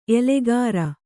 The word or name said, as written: ಎಲೆಗಾರ